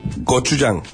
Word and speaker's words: Gochujang: Gochujang.